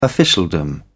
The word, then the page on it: officialdom: British English